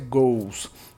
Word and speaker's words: goes: A pronúncia não é goes, tá? Goes é só como escreve, a pronúncia é goes. Como se fosse dois gols do seu time. Essa é a pronúncia. O verbo to watch, W-A-T-C-H, to watch, que é o verbo assistir.